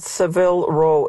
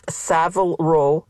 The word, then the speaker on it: Savile Row: It's not Savile Row, it's Savile Row. Seville is a city in Spain. The place where you can get really good, expensive suits, best suits in the world, Savile Row. Accent on the first syllable, and it's a letter A.